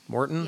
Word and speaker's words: Maurten: So, a bunch of people are asking about Martin, M-A-U-R-T-E-N. How do you, is that how you say it? Martin? – Morton.